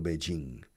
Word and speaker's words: Beijing: And do you remember Tiananmen Square in Beijing? In Peking? In English, you can say Peking or Beijing. Aquí en España se suele decir todavía Peking. You know, Beijing.